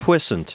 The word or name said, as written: puissant